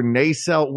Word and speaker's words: nacelle: And do you say nacelle or nacelle? Where's the accent on that word? – I am not the person to ask for correct pronunciation on anything, but I'm a nacelle.